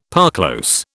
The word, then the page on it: parclose: British English